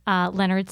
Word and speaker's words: Leonard: Leonard. Leonard spelled like the man's name.